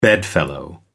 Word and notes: bedfellow: American English

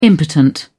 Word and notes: impotent: British English

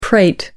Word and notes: prate: American English

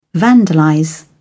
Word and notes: vandalize: British English

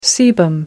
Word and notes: sebum: American English

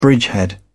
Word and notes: bridgehead: British English

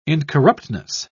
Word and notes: incorruptness: British English

